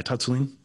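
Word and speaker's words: Tsatsouline: Did I say that right? – Yeah, Patsulin. Yeah.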